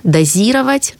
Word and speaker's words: дозировать: Перечень глаголов, где ударение падает на основу. Дозировать, закупорить, откупорить, кашлянуть, клеить... осведомиться, черпать и щелкать.